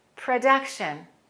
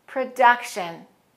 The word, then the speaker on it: production: And then production is the same sound as the O in some, production, production. I know English pronunciation can be crazy.